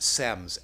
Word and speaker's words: sems: In Tibetan, the word sems, S-E-M-S, it's actually just pronounced sem. Sem.